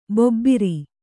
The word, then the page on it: ಭೂಭಾರ: bhū bhāra